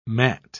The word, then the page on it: matte: British English